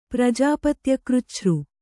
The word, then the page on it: ಪ್ರಜಾಪತ್ಯಕೃಚ್ಛø: kannada